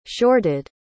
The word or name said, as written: shorted